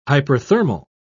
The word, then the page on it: hyperthermal: British English